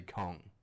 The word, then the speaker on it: Kong: You gotta say Kong. – Like that. – That's it. – Rolls off the tongue, Kong. – I don't know. – One syllable.